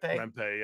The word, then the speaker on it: Rempe: I see, oh, Rempe, or is that how you say his name? Rempe? – Rempe, yeah.